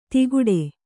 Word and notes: ತಿಗುಡೆ: tiguḍe

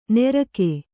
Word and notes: ನೆರಕೆ: nerake

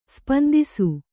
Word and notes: ಸ್ಪಂದಿಸು: kannada

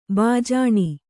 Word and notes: ಭೂಜಾನಿ: Kannada

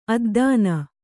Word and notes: ಅದ್ದನ: addana

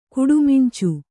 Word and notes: ಕುಡಿಮಿಂಚು: kannada

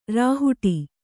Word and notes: ರಾಹುಟಿ: rāhuṭi

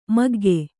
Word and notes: ಮಗ್ಗ: Kannada

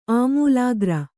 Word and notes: ಆಮೂಲಾಗ್ರ: āmūlāgra

